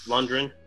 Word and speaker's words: Lundgren: Dolph Lundgren.